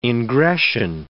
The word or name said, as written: ingression